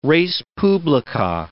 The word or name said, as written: res publica